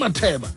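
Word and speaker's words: Matheba: You better say it right. Bonang Mateba.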